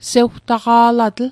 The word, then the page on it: sewtaqa'latl: Mi'gmaq